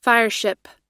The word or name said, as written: fireship